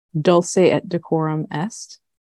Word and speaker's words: Dulce et decorum est: Dulce et decorum est. Did I pronounce that correctly? – I think you did the Latin right.